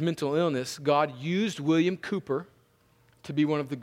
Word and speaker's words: Cowper: Now, it's spelled Cowper, but it's pronounced Cooper.